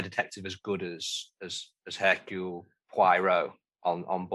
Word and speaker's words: Poirot: So this is the Detective Poirot, is that right? – Hercule Poirot.